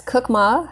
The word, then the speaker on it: Kukma: Did I say that right? – Yes, Kukma. However, you want to emphasize the ma.